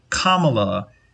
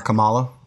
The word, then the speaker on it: Kamala: but I have been saying her name wrong for pretty much all of that time. So it's Kamala Harris, and I'm pretty embarrassed. But anyway. – You mean it's not Kamala or Kamala? – Or Kamala. Yeah. So yeah, I'm going to probably. – It's like the punctuation. I've been saying it wrong too. – Right. It's not the black version of Pamela.